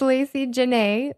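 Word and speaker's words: Laci Janea: It's Lacey Janae, but it's spelled LACIJANEA.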